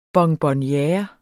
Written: bonbonniere

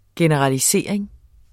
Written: generalisering

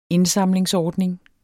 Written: indsamlingsordning

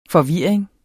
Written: forvirring

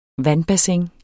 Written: vandbassin

